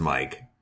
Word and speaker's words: Miike: I'm pretty sure it's pronounced Mike. M-I-I-K-E. It's a Starbucks cup version of Takashi Mike. – Yeah, but it's two I's, so it's Mike.